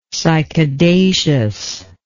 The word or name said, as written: cycadaceous